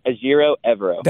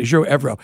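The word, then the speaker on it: Ejiro Evero: That's how do you pronounce his name, the D.C. for the Carolina Panthers? – Azero Evero. – That's it. I want to make sure I got it right. Azero Evero.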